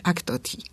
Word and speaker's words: Achtoty: Achtoty jest całkowicie autentyczne, powinno się to wymawiać Achtoty, ale ze względu na to, że to jest taka wdzięczna nazwa, czyli po prostu ach, to ty.